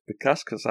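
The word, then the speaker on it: Bukauskas: Modesta Bukaska. – Bukaskus, I'm pronouncing it as. – Modescus Bukaskus. We'll go with that.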